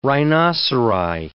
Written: rhinoceri

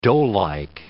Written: doughlike

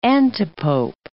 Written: antipope

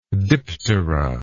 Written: diptera

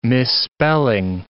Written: misspelling

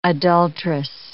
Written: adulterous

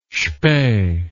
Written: spee

